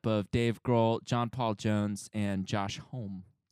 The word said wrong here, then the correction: Homme: Josh Homme. – Is that how you say it? – I think it's Homme, yeah.